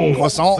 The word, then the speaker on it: croissant: Oh, Croissant. Thank you for saying it correctly.